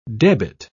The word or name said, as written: debit